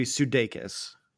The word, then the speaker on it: Sudeikis: From what I understand, it's supposed to be Sudeikis.